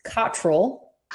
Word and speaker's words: Cottrell: Okay, so it's pronounced Cottrell, but Cottrell. – that's really, it's hard to say, and everyone messes it up, so do not worry at all.